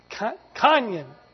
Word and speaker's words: Kanye: Kanye.